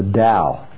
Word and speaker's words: Tao: Well, the premise of Lewis's argument is couched within the broad scope of what Lewis calls the Tao, spelled T-A-O, but pronounced Tao.